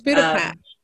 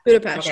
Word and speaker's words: Budapest: It's Budapest, is how they say it. – It's a E-S-H sound, right? – Yeah, Budapest.